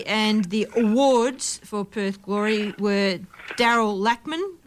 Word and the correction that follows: Latchman: Lachman, how do you say surname, Matt? Latchman. Latchman, Daryl Latchman, uh, MVP and Players Player.